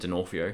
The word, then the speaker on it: D'Onofrio: Vincent D'Onofrio.